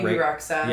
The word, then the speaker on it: Rexha: BB Rexha.